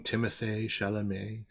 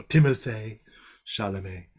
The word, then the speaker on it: Timothée Chalamet: And I'm sorry, I have to say his name like that because he has an accent aigu on his E, his double E at the end of his name. So Timothée Chalamet.